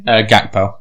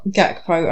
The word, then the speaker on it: Gakpo: Gakpo. – Gakpo.